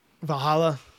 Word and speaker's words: Valhalla: Valhalla.